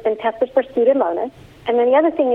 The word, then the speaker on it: Pseudomonas: Pseudomonas, which is a bacteria. Yeah, let me spell it. – Pseudomonas. – Yeah, there's a silent P. So P-S-E-U-D-O-M-O-N-A-S.